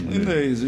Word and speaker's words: Ignês: A gente pronuncia o G, Ignês ou só Inês? – Inês, o G é mudo.